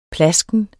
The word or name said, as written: plasken